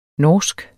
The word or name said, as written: norsk